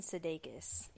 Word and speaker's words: Sudeikis: Jason, update, it was Jason Sudeikis.